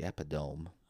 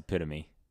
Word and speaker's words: epitome: No, the epidome. – I don't know what that means. I'll Google the example, it. Okay. The shining example of plastic. – That word is pronounced epitome.